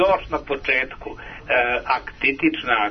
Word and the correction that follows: aktinična: ako dobro izgovaram, keratoza. – Aktinička je dobro? – Dobro, aktinična, dobro da zapamtim.